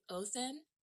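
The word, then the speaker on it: Óðinn: So Odin is spelled O, the D that curls with the line through it, I-N-N, that is pronounced Othin with a T-H.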